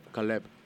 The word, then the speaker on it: Caleb: Caleb.